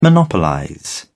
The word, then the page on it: monopolize: British English